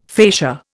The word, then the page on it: facia: Indian